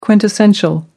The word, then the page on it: quintessential: American English